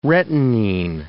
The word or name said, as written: retinene